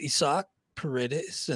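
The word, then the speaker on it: Isaac Paredes: I heard the announcers calling him that. I was like, no, that can't be right. Then I went to his baseball reference page. Indeed, it is pronounced Isak Paredes, and picked him up for two.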